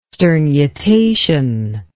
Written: sternutation

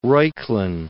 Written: Reuchlin

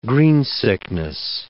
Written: greensickness

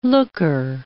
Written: looker

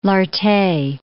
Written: Lartet

